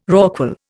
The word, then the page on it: rorqual: Indian